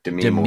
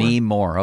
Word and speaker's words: Demi Moore: Emphasis on the me, Demi Moore. – Demi Moore, okay.